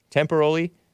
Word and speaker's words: temporally: Temporally is not pronounced the same as temporarily.